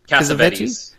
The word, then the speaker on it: Cassavetes: People, Nick, was it Cassavetes? Is that how you say his name? – Cassavetes.